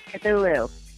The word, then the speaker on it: Cthulhu: It's pronounced Cthulhu.